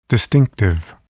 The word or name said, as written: distinctive